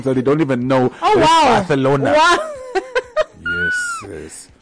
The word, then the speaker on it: Barcelona: It's actually not pronounced Barcelona. It's actually pronounced Barcelona.